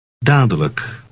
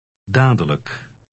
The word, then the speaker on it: dadelijk: Dadelijk. – Note that the last syllable is unstressed and thus is pronounced look. – Read 22 again. – Dadelijk.